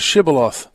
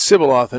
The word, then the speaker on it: shibboleth: The story of was it the Ephraimites who couldn't pronounce the SH, or one of the tribes had a funny accent and couldn't pronounce the SH, and the word for grain was a shibboleth. And they said it's Sibboleth, and if you said it wrong, across the border, they killed you.